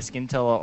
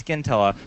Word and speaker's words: Scintilla: It can be either Scintilla or Scintilla. Doesn't matter to me. – Really? What do you prefer? – I personally always say it's Scintilla, but that was before I found out that it was an actual English word. – Oh. – Which is pronounced with the soft S.